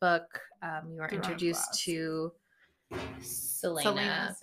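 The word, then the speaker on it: Celaena Sardothien: So if you're listening, I think I would play an amazing main character would be an amazing, how do you say her name in the first book, Selena? – Yeah, Selena Sardothian.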